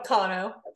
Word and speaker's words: Cano: I was just thinking that before we came out, Cano. People say Cano all the time, so it's totally fine.